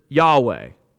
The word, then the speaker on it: Yahweh: Now the vowels there, we have no clue about, which is why for the longest time the vowels were supplied and we translated this Jehovah. That's where that comes from. But nowadays, most people would pronounce it Yahweh.